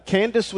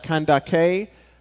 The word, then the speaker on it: Candace: He's an Ethiopian eunuch serving in the court of Queen Candace, would be the way we pronounce it. It may originally have sounded more like Kandake, but that's a title from what we can tell, like Pharaoh, that is passed down.